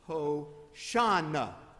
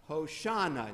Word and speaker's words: Hosanna: Okay, in Hebrew, this would be Hoshea na. So save. And then it sort of gets shortened into the New Testament instead of four syllables down to three. Hosanna. Hosanna. And you hear a little SH in there. And then you say, well, what happened to the SH? Well, in Greek, there is no SH.